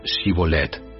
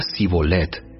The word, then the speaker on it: Shibolet: Ellos le pedían que pronunciara la palabra Shibolet. Si era de Efraín, diría Sibolet, porque a la gente de Efraín le cuesta pronunciar bien esa palabra.